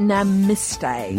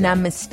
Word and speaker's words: Namaste: I just want to say it's pronounced Namaste. – Namaste. – Namaste.